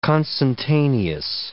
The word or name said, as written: consentaneous